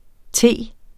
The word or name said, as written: t.